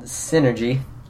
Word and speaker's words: Psynergy: Okay, so the magic system was called Synergy. It's spelled with a P-S-Y, so like P-S-Y-N-E-R-G-Y. And it's just like magic.